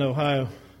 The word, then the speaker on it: Ohio: And how do you say that? Ohio. Kind of run it together or something.